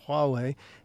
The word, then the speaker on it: Huawei: It's a funny spelling. It's spelled with an H at the beginning, but it's pronounced Huawei.